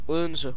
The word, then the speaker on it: Odense: En la ciudad de Odense, que se pronuncia en realidad así. Odense.